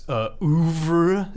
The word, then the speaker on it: oeuvre: But I think compared to Edgar Wright's oeuvre, is that how you pronounce that word? – Good word. Yeah, that's a college word.